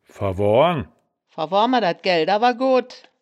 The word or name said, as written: verwoahren